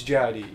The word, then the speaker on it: J.I.D.: It's J.I.D., yeah.